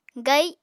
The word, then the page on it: gai: apatani